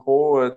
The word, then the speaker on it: Brault: Like, my name is Julien Brault. It's spelled B-R-A-U-L-T.